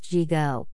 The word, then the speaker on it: Yigo: On the north tip of the island is the village of Yigo, pronounced Jigo. Charmoro pronunciation of Spanish words is different. Ys are always pronounced like J's. Double L's are not pronounced like a Y but also pronounced with the J sound.